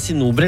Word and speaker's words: Sinober: Det sinober eller sinober? – Sinober.